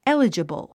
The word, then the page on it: eligible: élidʒəbl; エリジブル